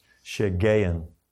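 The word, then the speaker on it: Shiggaion: is that it refers to itself as a S-H-I-G-G-A-I-O-N. It's pronounced Shigayon.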